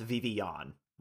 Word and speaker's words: Vivillon: There is no second L in that like middle part there, so it's supposed to be pronounced Vivillon. Like, I've always been saying it Vivillon.